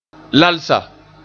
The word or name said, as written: lalsa